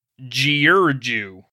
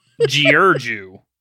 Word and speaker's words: Giurgiu: So, the coming months showed Vlad as having a strong military mind as well when he seized the fortress of, this is going to be horrible, I promise you, Giurgiu. I swear to God, that's how you pronounce it. – That's fine. – Giurgiu.